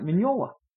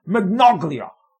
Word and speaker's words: Mignola: But there was a guy that was brought up named Mike Mignola. And Todd would, it was so memorable to me, because Todd would pronounce it Magnoglia. Mike Magnoglia.